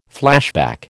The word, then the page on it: flashback: American English